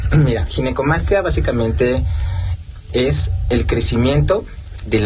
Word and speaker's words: ginecomastia: Pues muchas gracias, y sí, definitivamente creo que es una, para empezar la palabra, le decía, ¿cómo se pronuncia? ¿Ginecomastia? ¿Ginecomastia? – Sí, es, exacto, ginecomastia.